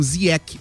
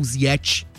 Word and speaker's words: Ziyech: É que a pronúncia é marroquina, né? Ele disse isso na entrevista coletiva, mas como ele jogou muito tempo na Inglaterra, lá era Ziyech.